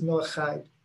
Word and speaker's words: Noahide: Yeah, so it's still pronounced Chide, because the CH actually in Hebrew is a Ch sound, the Chet. So it's Noahide. We just spell H in English, but CH, yes, that's exactly right.